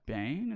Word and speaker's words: Bane: Bane is how you say his name.